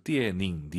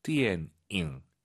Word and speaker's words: 电影: 초급자분들께서 띠에닝, 니은 발음으로 많이 해서 그래요. 띠엔잉 잊지 마시고요.